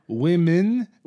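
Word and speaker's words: womyn: So they prefer to be called women, where it's W-O-M-Y-N. – Oh. – Women. So if you were to take... – Oh, because it kind of rhymes with hymen? – Yes, that's exactly why.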